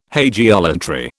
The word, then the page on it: hagiolatry: British English